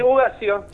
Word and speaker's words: Ugacio: Sí, pronunciaste bien mi apellido. Muchas gracias por la comunicación. – No lleva tilde, nada en el medio. – No, eso bien. Sí, Ugacio.